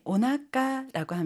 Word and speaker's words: お腹: 신체 일부분 배를 나타낼 때 오나카라고 합니다. 이때 나카는요, 우리가 배복이라고 하는 한자를 써요. 그런데 읽을 때는 나까라고 읽어요. 여러분, 나까는요, 우리가 가운데를 나타내잖아요. 그래서 신체 중에서 가장 가운데에 있다라는 의미를 담아서 배복을 나까라고 읽는데요. 이 경우에는 앞에 오가 꼭 붙어야 돼요. 그래서 오나까 배라고 합니다.